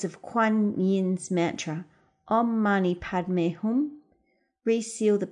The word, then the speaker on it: Om Mani Padme Hum: That's Om, O-M, Mani, M-A-N-I, Padme, P-A-D-M-E, and Hum, H-U-M. Sometimes it's pronounced Om Masi Padme Hum, but we're going to go with the Om Mani Padme Hum.